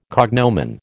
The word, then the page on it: cognomen: American English